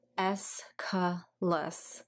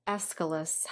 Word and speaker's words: Aeschylus: I looked up a couple pronunciations of his name, and phonetically, Aeschylus is how I saw it pronounced.